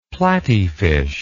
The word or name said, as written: platyfish